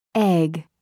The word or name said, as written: egg